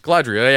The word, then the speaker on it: Galadriel: When, like, the Fellowship gets all their cool stuff from Galadriel. Did I say that right? I had a Red Bull. Galadriel, yeah. My brain's mush right now. I had Red Bull.